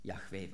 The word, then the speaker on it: Jahwe: die klar machen, dass die Aussprache zur Zeit Jesu Jahwe war.